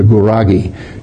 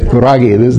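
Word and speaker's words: Gurage: You know how you feel in the morning when you wake up? You're groggy, right? That's it, Guragi. They pronounce it a little different, but that's the way you say it.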